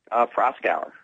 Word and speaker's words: Proskauer: Proskauer.